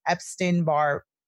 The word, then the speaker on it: Epstein-Barr: So I also found one of your posts, at least 90% of us have had EBV virus, and that is the Epstein-Barr virus.